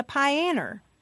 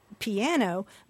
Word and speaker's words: piano: people also talk about playing the pianer. I mean, my dad used to go tell me to go practice the pianer, and he was making a joke because he knows that the standard pronunciation is piano, but he was imitating the language of his parents.